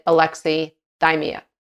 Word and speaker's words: alexithymia: And those of us who struggle to be able to identify our emotions, and some people will call this alexithymia, or depending on how you pronounce it, alexithymia, alexithymia. Some people pronounce it differently, but I pronounce it alexithymia.